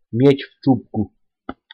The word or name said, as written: miec w czubku